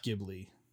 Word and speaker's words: Ghibli: It's pronounced Ghibli.